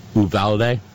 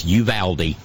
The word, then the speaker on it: Uvalde: I was saying Uvalde. Nope. It's a Texas word, and it's Uvalde.